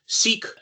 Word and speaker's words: Sikh: He is a Sikh. Am I saying that right? – A lot of people also pronounce it Sikh. – Yes, yeah. Okay. However you choose to pronounce it, Sikh or Sikh, presumably of Indian ancestry, mm-hmm.